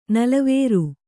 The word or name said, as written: ನಲವರಿ